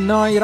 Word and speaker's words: Neo: Siete all'ascolto di Noi Radio, si scrive Neo, si pronuncia Noi e vuol dire nuova emittente urbana, neoradio.it in diretta da Bologna come detto.